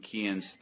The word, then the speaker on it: Cian: Miles is 17 and Kian's 13. – Kian. – Yep. – How do you spell it? – C-I-A-N. It's Gaelic. It's Irish.